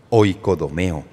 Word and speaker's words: oikodomeo: Ahora bien, la palabra edificación es traducción de una palabra griega que se compone de dos palabras y en el griego se pronuncia de esta manera, oikodomeo.